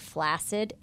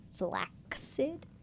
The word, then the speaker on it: flaccid: So you all were talking about, can't remember if it was yesterday or 2 days ago, the word flaccid, and it was flaccid. – Flaccid. – F-L-A-C-C-I-D. But the first C is silent.